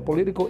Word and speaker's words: political: Entonces, se, también tenemos una T otra vez aquí de nuevo, pero esta vez la vamos a pronunciar como una R. Entonces sería político. Dice a political issue.